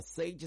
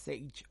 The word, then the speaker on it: Xage: Sage is spelled X-A-G-E, Sage Wild, that's the name of the artist. So it's spelled X-A-G-E-W-I-L-D, Sage Wild.